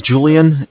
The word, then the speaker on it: Julian: My name is Julian. It's J-U-L-I-A-N. – Okay. – I'm calling with Bank of Cardiff. Cardiff is spelled C-A-R.